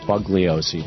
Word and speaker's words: Bugliosi: And in fact, Vince Bugliosi, it's spelled like it's pronounced Bugliosi, but he drops the G.